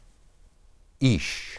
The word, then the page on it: ish: Hebrew